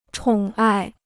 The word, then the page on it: 宠爱: chǒng ài